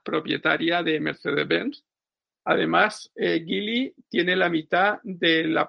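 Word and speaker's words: Geely: Eh, pido perdón a, los que ofenda esta declaración, por lo que ya conocía al, gigante Gili, como dicen los sajones, que se escribe Geli,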